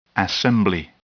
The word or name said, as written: assembly